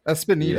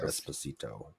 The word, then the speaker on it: Esposito: Espinito.